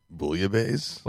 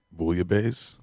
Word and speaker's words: Bouillabaisse: B-boy, but how'd you pronounce it again? – Bouillabaisse. – Bouillabaisse? So B-boy, bouillabaisse. – I don't know if I'm like, if that's the most correct. I don't know. – B-boy. – I'm not an expert. – B-boy, bouillabaisse. – You got it terribly wrong.